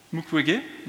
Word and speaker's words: Mukwege: Donc sur la gauche, on a Denis Mukwege, si je le prononce bien. Mukwege, merci.